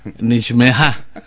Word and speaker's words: Nijmegen: Jadi New Start Jalan Baru, New Mark Pasar Baru, kota besar Den Haag, Rotterdam, Eindhoven, Nijmegen.